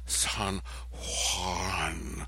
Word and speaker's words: San Juan: You're supposed to sort of breathe out and say, San Juan, San Juan, San Juan. You don't put your tongue, San Juan. You keep your tongue on the bottom, San Juan. That's how they say it there.